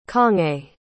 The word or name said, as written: congé